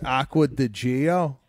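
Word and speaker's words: Acqua di Giò: I think the Aqua di Gio is a very safe play. I mean, it goes right in. – Okay. Watt, let me step in here. It's Aqua di Gio.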